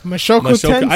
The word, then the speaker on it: Mushoku Tensei: Mushukaku ga. – Mashoku Tensei.